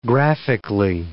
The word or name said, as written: graphically